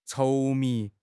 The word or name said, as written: 稠密